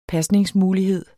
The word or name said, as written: pasningsmulighed